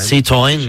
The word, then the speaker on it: Citroën: Citroën. – Citroën. – Sí, muy bien. – Ah, muy bien. – Yo creo que mejor incluso Alex de Llano. – Très bien. – Chicos, un dato relevante. – Citroën.